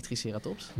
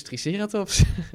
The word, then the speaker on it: Triceratops: Nou, dat is dus Triceratops. maar dat is dan de Engelse klemtoon.